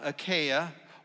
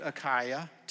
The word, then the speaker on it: Achaia: There in the left-hand corner, you have Greece at the bottom, Achaia, or you can pronounce it Achaia, tomato, tomato, whichever way you want to go.